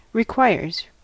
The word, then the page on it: requires: American English